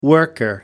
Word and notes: worker: American English

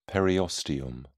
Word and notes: periosteum: British English

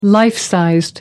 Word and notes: life size: American English

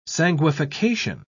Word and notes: sanguification: British English

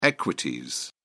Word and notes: equities: British English

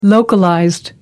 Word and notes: localized: American English

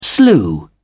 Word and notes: slew: British English

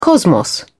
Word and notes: cosmos: British English

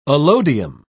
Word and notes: allodium: British English

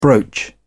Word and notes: brooch: British English

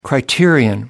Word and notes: criterion: American English

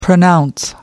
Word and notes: pronounce: American English

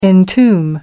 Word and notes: entomb: American English

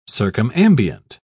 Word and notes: circumambient: British English

